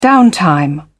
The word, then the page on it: downtime: British English